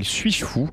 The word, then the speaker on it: S8JFOU: Et en l'occurrence, un matin, on avait sélectionné un artiste qui s'appelle Suis-je fou et qui s'écrit S8JFOU, donc le 8 faisant office de 8 pour Suis-je fou, pour info si vous voulez retrouver.